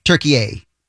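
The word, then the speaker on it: Türkiye: By the way, apparently the official pronunciation of the new Turkey name is Turkey A.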